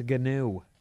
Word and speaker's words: gnu: Is that how you say it? Gnu? – No, it's a gnu.